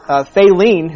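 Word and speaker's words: Faline: And then there's I think her name is pronounced Faylene, who's like who's the girl deer that Rano and Bambi both like.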